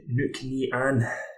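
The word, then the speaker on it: Nc'nean: First whiskey up is the very hard to pronounce, but I think I'm getting it correct when I say it's Knocknean. – Yeah, I would always look at it and think Knocknean. I've got a wee bit of research on it to see how it would be pronounced. Right, Knocknean.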